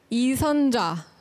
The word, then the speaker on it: 이선자: 이선자. – 이선자. Wow, your pronunciation. That was so Korean.